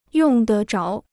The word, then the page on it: 用得着: yòng de zháo